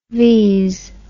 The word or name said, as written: vees